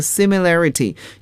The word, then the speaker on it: similarity: 那么通常 similar 我们也可以把它变作名词 similarity，也就是在 similar 的词尾添加 ity 这个拉丁语中的名词后缀 ity，可是发音呢就要发生了变化，形容词 similar，名词 similarity。